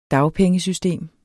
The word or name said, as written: dagpengesystem